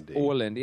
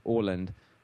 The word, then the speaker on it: Åland: Okay, and it looks to me like spelled A-L-A-N-D, and you pronounce it O-L-A-N-D. – It's Åland. Yeah, it's actually, if you look closely, the A has a circle over the top of it, and that's a letter that they have in the Swedish alphabet that we don't have in English, which is pronounced Å, kind of like O-A together. So it's actually pronounced Åland, which is how you say it.